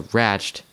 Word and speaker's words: Ratched: The battle of wills between the rebellious McMurphy and the inflexible Ratched, or Ratched, that's right how to pronounce that, soon affects all the ward's patients.